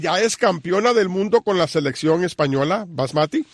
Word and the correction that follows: Bonmatí: Bonmatí, perdón, tengo que pronunciarlo como catalán. Bonmatí.